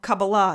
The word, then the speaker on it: Kabbalah: And there is actually speculation that The foundation of the Kabbalah system, or Kabbalah as it's properly pronounced, that the basic tenets correspond to the yogic system, the Hindu system of chakras.